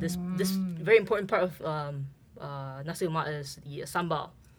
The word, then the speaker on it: nasi lemak: So the first word is nasi, rice. It's spelled N-A-S-I. – Mm-hmm. Mm-hmm. – And the second word, lemak, L-E-M-A-K. So nasi lemak.